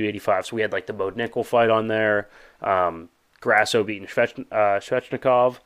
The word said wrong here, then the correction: Shevchenko: Shevchenko, my bad. I always mispronounce it.